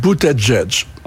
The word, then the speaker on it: Buttigieg: Ça se prononce, d'après lui, je le cite, Buttigieg. – Oui, absolument. – C'est comme ça qu'il le prononce lui-même.